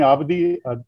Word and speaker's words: Abidi: Yeah, you can call me Ali, you can call me Abidi. – Abdi. Oh my God, all this time I have been taking your name wrong.